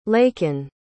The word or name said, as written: Laken